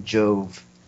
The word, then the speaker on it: JOEV: Hello, I'm JOEV, this spelled with an E before the V, because I'm a magician type person, and that's what they do with magic things. But you can call me Joe if that one syllable is too much for you. – So your name's... – My name is Magic. It's J-O-E-V.